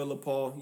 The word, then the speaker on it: LePaul: And it's your boy, LePaul. Yes, L-E, Paul. Say it like you French or don't say it at all.